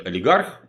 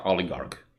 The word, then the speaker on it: олигарх: Обратите внимание, что, как и другие слова греческого происхождения, оно читается не олигарх и не э, олигарх, да, олигарх.